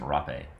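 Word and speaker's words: rapé: So the first thing was this thing called uh, rape, which is spelled like rape, uh, but mm-hmm. it's rape.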